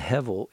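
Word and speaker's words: hevel (הבל): It's hevel, and hevel is how we pronounce Abel.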